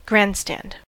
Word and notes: tribune: /tʁi.byn/

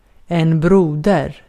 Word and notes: veli: [ˈʋeli]

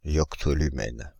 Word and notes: yoctolumen: [jɔk.tɔ.ly.mɛn]; /jɔk.to.ly.mɛn/

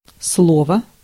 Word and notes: speech: /ˈspiːtʃ/

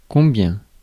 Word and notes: combien: France; [kɔ̃.bjɛ̃]; /kɔ̃.bjɛ̃/

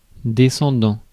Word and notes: descendant: /de.sɑ̃.dɑ̃/; France

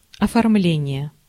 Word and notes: decoration: /ˌdɛkəˈreɪʃən/

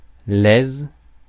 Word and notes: satisfaction: American English

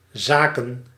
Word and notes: занятие: /zɐˈnʲætʲɪjə/

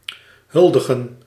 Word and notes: honneur: /ɔ.nœʁ/; /ɔnɶ̝ʁ/

